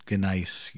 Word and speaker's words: gneiss: The soil is mostly granite, and how do you pronounce it? Gneiss, yes.